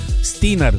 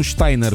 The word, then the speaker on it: Steiner: După ce i-au criticat, domnul și doamna se numesc Ina și David Steiner, nu Steiner, cum ați fi, poate v-ați gândit să spuneți. Acum în Statele Unite nu se mai pronunță Steiner, dacă se scrie Steiner, se pronunță Steiner, da?